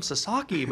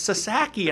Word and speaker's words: Sasaki: He goes, they keep calling him Sasaki, but it's Sasaki.